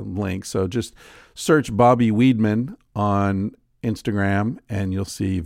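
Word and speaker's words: Weidman: I think it's pronounced Weedman. It's W-E-I-D-M-A-N.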